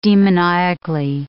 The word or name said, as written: demoniacally